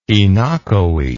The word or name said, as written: oenochoe